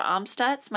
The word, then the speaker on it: Amstutz: You can find me on all platforms at Sandra Amstutz.